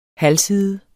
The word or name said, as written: halvside